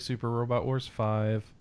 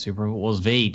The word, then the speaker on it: Super Robot Wars V: And Alice always says I should play Super Robot Wars 5. – Yeah, play it. And it's not Super Robot Wars 5. It's Super Robot Wars V.